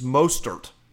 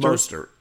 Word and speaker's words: Mostert: It's Mostert. I think that's how you say it.